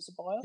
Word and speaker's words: Ceballos: Is it Ceballos? – Yeah, bloody hell.